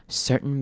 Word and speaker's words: certain: Depression can be caused by things like stressful life events, certain medications. 好，注意到这边 certain 这个字要注意的是后面 tain 出现过很多次，这边要念成嗯。就用一个嗯的声音带过，也是一样 Sir 要把 R 的声音咬完之后再念一个嗯的声音。